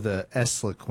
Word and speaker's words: Eslick: Also new to the teaching area over at Winoka, Shannon Eastlick. Am I saying that correctly? Eastlick, E-S-L-I-C-K? – It's Eslick.